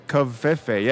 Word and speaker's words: Covfefe: Covfefe. Yeah, you did it good.